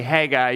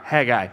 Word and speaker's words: Haggai: And then all of a sudden, whiplash, we're going to be talking about the Old Testament book of Haggai, however you pronounce that. I say Haggai usually, and then I've been corrected a couple of times, and here we are, Haggai.